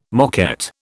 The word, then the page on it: moquette: British English